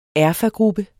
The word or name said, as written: erfagruppe